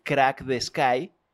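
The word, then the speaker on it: Crack the Skye: que es el Crack the Sky, como se pronuncia, aunque se escribe Sky, que en español es cielo. Se escribe distinto, ¿no? Hablaremos también sobre eso, que se escribe S-K-Y-E.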